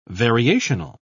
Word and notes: variational: British English

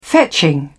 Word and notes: fetching: British English

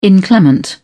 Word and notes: inclement: British English

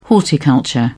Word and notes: horticulture: British English

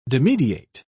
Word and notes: dimidiate: British English

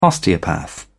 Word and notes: osteopath: British English

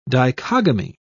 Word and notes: dichogamy: British English

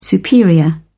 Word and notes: superior: British English

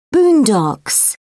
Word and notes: boondocks: British English

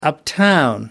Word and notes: uptown: American English

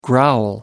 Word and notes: growl: American English

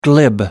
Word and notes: glib: American English

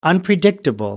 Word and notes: unpredictable: American English

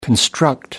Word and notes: construct: American English

